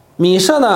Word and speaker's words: 米舍: 啊，和这个词读音是一样的。米舍呢，和这个土巴和米舍靠近希腊，在今天这个土耳其境内，啊，以及结束二十七章十三节，土巴人、米舍人是干什么的呢？